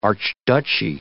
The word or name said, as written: archduchy